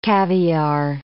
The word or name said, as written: caviar